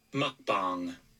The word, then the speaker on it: mukbang: Mukbang.